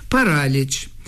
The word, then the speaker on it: параліч: Параліч. Саме такий наголос у цьому слові, грецькому за походженням, від грецького паралісі.